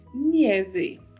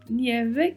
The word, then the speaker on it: nieve: e particularidade de pronúncia aí é a letra E, que também é fechada, né? Nieve, e a letra V com leve som de B. Nieve, nieve. E muito cuidado com o I de nieve, ele tem que aparecer na pronúncia. Então temos que falar nieve.